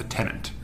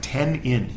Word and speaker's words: tenant: And the reason I wanted to point this out to you, the English word, is because in the U.S., since we speak improper English, a lot of times we say it like tenant. The tenant. It's almost like ten in, you know?